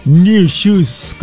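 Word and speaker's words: Nu Shooz: Se llama esta banda I Can't Wait del 86, pero ellos no escriben New Shoes como nuevos zapatos o zapatos nuevos, sino NU. S-H-O-O-Z. Un desastre. Pero bueno, es New Shoes con I Can't Wait. Igual se pronuncia de la misma forma.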